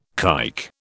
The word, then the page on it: kike: British English